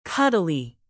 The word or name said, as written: cuddly